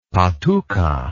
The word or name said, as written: patuca